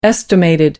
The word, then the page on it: estimated: American English